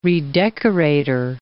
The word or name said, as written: redecorator